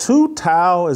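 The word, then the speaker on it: Tou Thao: And is it Tu Tao? Did I get the name right of the Asian officer? Yeah.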